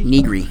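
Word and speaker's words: Nigri: Like when I see, I think it was Jessica, I never get her name right. Negri? – Negri. Negri, yeah.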